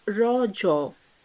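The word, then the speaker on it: Raja: The festival is natively pronounced as rojo, a local term that means menstruating woman.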